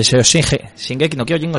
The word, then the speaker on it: Shingeki no Kyojin: Pues bien, para este primer descanso os voy a dejar con el segundo opening de Shinheki no Hyojin. Eh, perdón, no se dice así. – Eh, Shingeki no Kyojin, os dejo con el segundo opening.